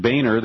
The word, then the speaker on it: Boehner: And here's a quote from John Boehner. That's the way he likes to pronounce his name. It's actually Boehner in German.